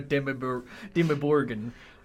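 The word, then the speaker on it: Dimmuborgir: Did you guys go see the chimneys in Dimmerburger? – Dimmerburger? Actually, it's a pretty good pronunciation. It really was kind of a Dimmerburgen.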